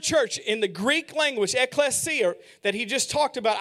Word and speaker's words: ekklesia: The word is ekklesia.